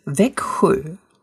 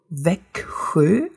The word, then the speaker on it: Växjö: Das ist Wekhö. Wekhö, also K und dann dieser SCH-Laut.